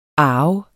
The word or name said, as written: arv